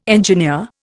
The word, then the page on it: engineer: Indian